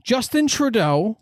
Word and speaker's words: Justin Trudeau: Justin Trudeau.